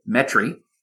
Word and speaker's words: Metairie: For a while, anyway, he was working out of Metairie, Louisiana, or as we have to say, Metairie. – Metairie, yes. Metairie. I was very confused when you pronounced it Metairie.